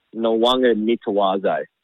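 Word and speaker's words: Nawaqanitawase: But I think it's Nawanga Nitawase. – Mate, you nailed it. That's perfect.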